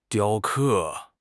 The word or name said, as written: 雕刻